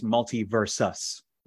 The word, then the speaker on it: MultiVersus: It's not MultiVersus, MultiVersus, versus, as in me versus you.